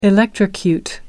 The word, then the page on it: electrocute: American English